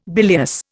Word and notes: bilious: Indian

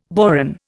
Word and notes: boron: Indian

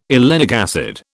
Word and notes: oleic acid: British English

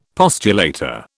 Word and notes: postulator: British English

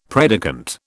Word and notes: predicant: British English

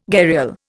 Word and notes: gharial: Indian

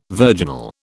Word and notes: virginal: British English

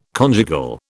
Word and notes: conjugal: British English

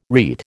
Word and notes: rete: American English

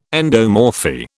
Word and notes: endomorphy: British English

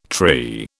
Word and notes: tree: British English

